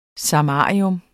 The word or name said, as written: samarium